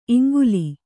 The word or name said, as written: ಇಂಗುಲೆ